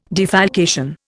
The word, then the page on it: defalcation: Indian